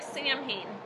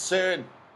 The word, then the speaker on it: Samhain: And it's S-G-H-H-L-G. – Samhain. It looks like Samhain. – Yeah. Sam. – But it's pronounced Sewin. – Yes, Sewin.